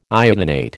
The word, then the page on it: iodinate: American English